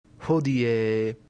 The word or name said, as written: hodie